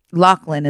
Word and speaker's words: Loughlin: Loughlin, is that how you say it? – Yeah.